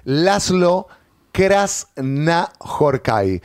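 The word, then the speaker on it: László Krasznahorkai: Y estamos hablando de, yo sé que es un pasaporte al furcio, Laszlo Krasznahorkai, ganador del Premio Nobel de Literatura 2025, y vamos a hablar con Maxi Papandrea.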